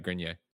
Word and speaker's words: Grenier: If it's French, then it's Grenier. If it's not French, I would say Grenier. – Okay. Oh, but I can't tell. Says Greg said it right. Grenier.